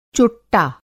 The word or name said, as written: चोट्टा